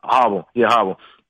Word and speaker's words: Harbaugh: Harbaugh.